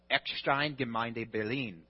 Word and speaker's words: Eckstein Gemeinde: I brought back that information, and we began supporting Marco and Katrina Bartolome as one of the lead pastors of Eckstein Gemeinde Berlin. That's how you say it in German.